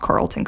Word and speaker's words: Carleton: She always had a love for nature and the outdoors and would go on to study geology at Carlston College in Northfield, Minnesota. I think that's pronounced Carleton College, not Carl's.